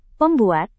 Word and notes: pembuat: Indonesian

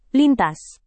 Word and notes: lintas: indonesian

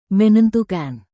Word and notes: menentukan: Indonesian

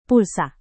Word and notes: pulsa: Indonesian

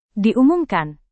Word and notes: diumumkan: Indonesian

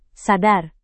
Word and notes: sadar: indonesian

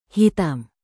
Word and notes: hitam: Indonesian

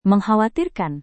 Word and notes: mengkhawatirkan: Indonesian

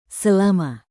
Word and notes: selama: indonesian

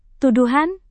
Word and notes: tuduhan: Indonesian

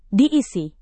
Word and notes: diisi: indonesian

